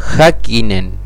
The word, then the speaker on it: Häkkinen: Después en 1968, el nacimiento de Mika Häkkinen. – Bien, bien, espectacular. Así se pronuncia.